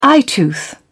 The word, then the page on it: eyetooth: British English